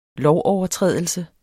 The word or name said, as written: lovovertrædelse